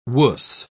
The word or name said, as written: wuss